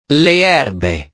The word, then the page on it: le erbe: italiano